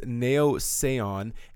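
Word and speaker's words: Naiossaion: The band name is Nao Sayon, and it's spelt very strange. It's N-A-I-O-S-S-A-I-O-N. So it doesn't really spell phonetically.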